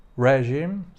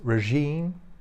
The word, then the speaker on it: regime: Na przykład Patryk Jaki mówi regime zamiast regime. Tutaj akcent pada na drugą sylabę w przeciwieństwie do języka polskiego.